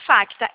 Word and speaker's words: Facta: Não, vai estar escrito Facta, FACTA. Aí já vai aparecer Facta Financeira. – Não está aparecendo aqui, vou digitar aqui, peraí, pessoal. – Tá, ó, FACTA, C é mudo, tá? – Pronto, já está os pisos.